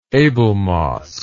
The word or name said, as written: abelmosk